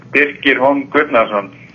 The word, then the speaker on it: Birkir Hólm Guðnason: Yeah, it's Birkir Hólm Guðnason.